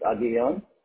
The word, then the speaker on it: Aguillon: It's Aguillon. – How do you spell that? – A-G-U-I-L-L-O-N.